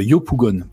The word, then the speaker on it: Yopougon: Alors pour le coup, euh, le récit, donc j'avais oublié le nom, c'est Aya de Yupogon. Euh, Yopougon, pardon, euh, pour la prononciation.